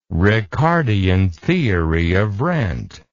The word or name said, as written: ricardian theory of rent